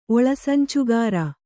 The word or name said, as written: ಒಳಸಂಚುಗಾರ